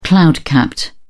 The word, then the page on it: cloud capped: British English